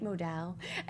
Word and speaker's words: modal: Every pair of MeUndies is sustainably sourced and made from micromodal, am I saying that right? Modal.